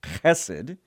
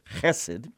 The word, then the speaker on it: chesed: Verse 10, but showing steadfast love, that's the Hebrew word chesed. You can spell it H-E-S-E-D, but it's got that hard H sound at the front of it, chesed.